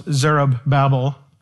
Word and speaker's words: Zerubbabel: Then Zerubbabel, I said last week, I think his name is best pronounced Zerubbabel, the one who was born in Babylon.